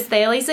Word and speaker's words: Thales: So it is Thales Academy.